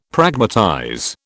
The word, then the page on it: Pragmatize: British English